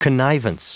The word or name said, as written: connivance